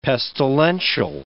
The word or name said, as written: pestilential